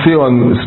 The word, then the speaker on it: Sihon: Sion. S-I-H-O-N. Sion. Well, so how do you pronounce that? Sion. – Zion. – Zion, okay.